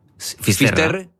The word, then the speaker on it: Fisterra: Entonces, llegó a Finisterre. ¿Se dice así? – Fisterra. – Es como se ponen los gallegos también, en fin.